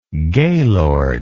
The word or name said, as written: gaylord